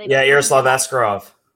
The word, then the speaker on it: Yaroslav Askarov: Maybe, you know, the Russian that they currently have, you know, Yaroslav Askarov.